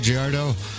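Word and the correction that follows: Girardot: Gerardo. Gerardo. – yes, like Merlot or Cabernet.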